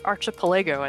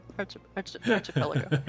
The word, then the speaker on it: Archipelago: Or Archipelago, as I used to pronounce it when I was a kid. – Something about Legos, whatever. – I learned how to pronounce things by reading them and not actually hearing them spoken. – It's just a series of islands created by small, connectable blocks, right? – Archipelagos. It's Lago, Archipelago. Archipelago.